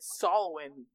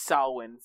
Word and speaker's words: Samhain: showing that she it, it, it's they call it Solwin, but it's spelled Samhain, and I think they call it Samhain in Halloween too.